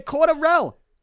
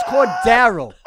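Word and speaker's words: Cordarrelle: If you look at the Patriots If you look at the Patriots, the yearbook or whatever they have, the media guide, they have the pronunciation. Go to Pro Football Reference. The pronunciation's there. It's going to say Cordero.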